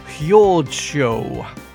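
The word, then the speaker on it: Jordsjö: So that is J O R D S J O and uh, I can tell you that, uh, that that name is split into two, if you like.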